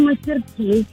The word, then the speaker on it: мастерски: Ну, вообще, я говорю мастерски на последнее «и».